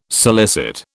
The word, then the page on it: solicit: British English